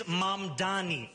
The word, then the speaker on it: Mamdani: And furthermore, the name is Mamdani. M-A-M-D-A-N-I.